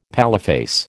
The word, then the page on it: paleface: American English